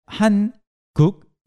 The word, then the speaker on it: 한국: Please also note that 한국 is pronounced 한국. – 한국. – It is not 한국. When you say it really quickly, you naturally have a liaison there. So it becomes something like 한국.